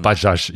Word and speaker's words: Bajaj: Genau, Bajaj. Ich habe mal nachgehört. Okay. Ich glaube, so wird das ausgesprochen. Bajaj, okay.